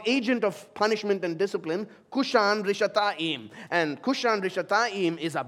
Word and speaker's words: Kushan Rishathaim: In Hebrew, Mesopotamia there is the longer name is given, which is Aram Naharaim, which rhymes with his name, Kushan Rishathaim.